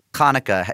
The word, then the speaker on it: Hanukkah: In Hebrew, Hanukkah is spelled with a chet, which has a little ch at the beginning, the ch that you'll hear in challah bread or chutzpah.